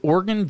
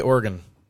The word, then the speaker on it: Oregon: And it's more like Oregon. – Like Oregon. Like I'm an organ donor.